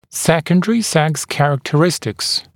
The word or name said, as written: secondary sex characteristics